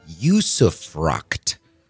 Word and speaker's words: usufruct: And today, Coach, the term is usufruct. It's spelled U-S-U-F-R-U-C-T.